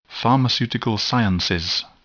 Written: pharmaceutical science

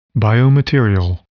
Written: biomaterial

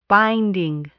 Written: binding

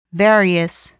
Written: various

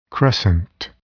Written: crescent

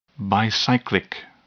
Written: bicyclic

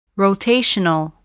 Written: rotational

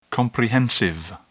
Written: comprehensive

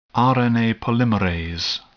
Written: RNA polymerase

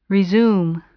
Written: resume

